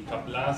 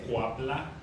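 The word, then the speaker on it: Qapla': No es Cuapla, es Capla.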